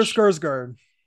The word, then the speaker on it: Sarsgaard: What was it, Peter Sarsgaard or something like that? I'm butchering the last name. – Peter Sarsgaard.